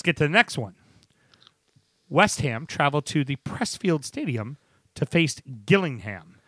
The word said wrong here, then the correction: Priestfield: Is it Priestfield or Priestfield? It's Priestfield. Sorry, okay. I mispronounced it.